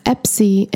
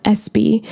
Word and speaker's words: Espy: And unfortunately, when I was putting that information into my notes for the show, I spelled it incorrectly, and therefore I pronounce it incorrectly as Epsi and not Espy.